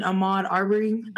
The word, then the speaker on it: Ahmaud Arbery: or even Ahmaud Arbery. Hope I'm pronouncing that right. – Yep, that's right.